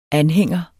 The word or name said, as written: anhænger